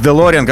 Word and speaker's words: Делориан: Я серьезно, с Делориан, как правильно говорить.